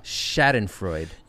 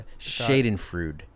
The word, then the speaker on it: Schadenfreude: Little Schadenfreude. – You gotta. – I mispronounced that. Schadenfreude.